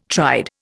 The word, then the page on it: tried: Indian